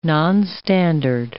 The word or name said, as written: nonstandard